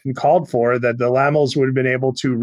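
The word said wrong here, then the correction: Laemmle: I think it's pronounced Lemley. – Lemley, that's what I meant.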